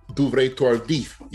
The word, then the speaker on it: Duvernay-Tardif: Laurent Duvernay-Tardif. I think that's how you pronounce Tardif. Duvernay-Tardif, yeah. – There you go. That's a better pronunciation than I got.